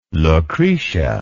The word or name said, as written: Lucretia